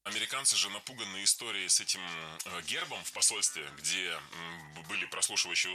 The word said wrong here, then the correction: гербом: Не гербом, а гербом. В этом слове при склонении ударение переносится на окончание. Поэтому гербом, гербами, гербах, гербы похожи на рассказчиков судьбы, семейные фамильные гербы.